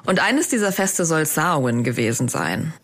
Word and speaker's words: Samhain: Ich spreche das aber Samhain aus, weil das auf Gälisch so ausgesprochen wird. Gälisch, das ist die ursprüngliche irische Sprache und in der werden ganz viele Wörter ganz anders ausgesprochen, als sie geschrieben werden.